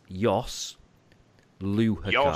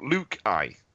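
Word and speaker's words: Jos Luhukay: So it's Jos Lehuque. – Lehuque. – It's not, though, is it? I'm pretty sure you wrote it phonetically on Twitter and you wrote Luke I. – That's before I had an audience with a German football expert who set me right. But it's quite a soft H in the middle.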